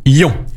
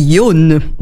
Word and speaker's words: Yon: Donc, cela se dit Yon. – Bien sûr. – Tout à fait. En bon japonais, et ça correspond donc au chiffre 4.